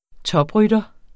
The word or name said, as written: toprytter